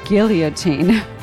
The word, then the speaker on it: guillotine: We heard Under the Guillotine, although they do pronounce it Under the Guillotine, which is really charming.